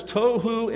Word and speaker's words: tohu: Formless in Hebrew is the word tohu, T-O-H-U, tohu, and it's defined as chaotic or lacking structure. Listen, there's no coincidence. If tohu means chaotic and lacking structure, there's absolutely no coincidence that tohu rhymes with tofu.